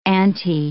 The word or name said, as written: antae